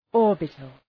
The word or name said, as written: orbital